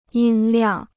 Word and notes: 音量: yīn liàng

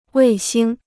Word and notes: 卫星: wèi xīng

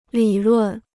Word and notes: 理论: lǐ lùn

